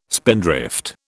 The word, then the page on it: spindrift: British English